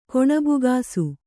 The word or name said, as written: ಕೊಣಬುಗಾಸು